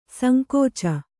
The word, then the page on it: ಸಂಕೋಚ: sankōca